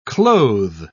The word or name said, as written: clothe